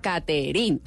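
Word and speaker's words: Caterin: Bueno, arroba Katherine, mi nombre, se escribe lo más criollo colombiano, porque creo yo, es con C, sin H y sin E al final. Caterín.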